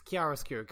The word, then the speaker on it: chiaroscuro: Yeah, real fucking chiaroscuro hours, or however you pronounce that dumb Italian word for jerks. – It's chiaroscuro.